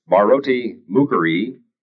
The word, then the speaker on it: Bharati Mukherjee: It's Bharati Mukherjee.